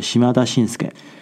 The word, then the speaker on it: 島田紳助: 当时我在思考的时候，想起一个日本的艺人，他的名字叫做呃岛田伸助，日本的发音是西马达信斯盖。